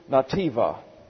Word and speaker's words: netiba: It is the Hebrew nativa. It's spelled N-E-T-I-B-A. Nativa.